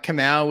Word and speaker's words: Kamau: Like the Bruce Kamau announcement where they tried to pretend like Kamau was Kapow from Batman. – Yeah, everyone was like, oh, like, what are these clues? And it turns out that the last name sort of rhymes with Kapow.